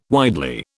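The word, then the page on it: widen: British English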